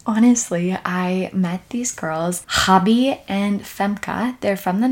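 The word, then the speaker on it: Gabie: I've never met anyone who spells the way I do, but you pronounce it Hobby because that's Dutch.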